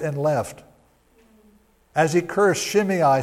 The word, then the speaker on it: Shimei: His name was Shimei. That's how you pronounce that name. Shimei, son of Gera, and he cursed as he came out.